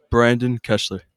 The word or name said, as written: Kessler